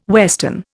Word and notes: western: Indian

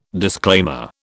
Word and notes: disclaimer: British English

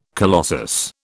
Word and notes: colossus: British English

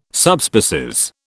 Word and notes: Subspecies: British English

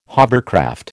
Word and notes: hovercraft: American English

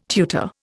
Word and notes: tutor: Indian

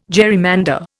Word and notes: gerrymander: Indian

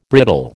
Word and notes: brittle: American English

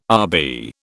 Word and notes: Rb: British English

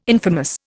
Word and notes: infamous: Indian

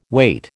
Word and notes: wait: American English

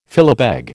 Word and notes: philabeg: American English

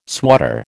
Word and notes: swatter: American English

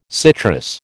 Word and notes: citrus: American English

